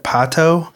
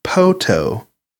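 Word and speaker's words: Poteau: The town in Oklahoma, we said Pato, but one of our listeners reached out and told us that it's actually Poto, like Phantom of the Opera.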